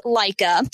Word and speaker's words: Leica: It's pronounced Leica, and Leica cameras have been around for a while.